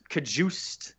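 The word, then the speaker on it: Cajuste: Is that really how it's pronounced? – I'm pretty sure it's Kajuste.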